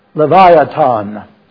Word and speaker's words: Leviathan: If your translation has that word, it's spelled L-E-V-I-A-T-H-A-N. Leviathan is the way you pronounce that.